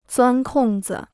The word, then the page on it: 钻空子: zuān kòng zi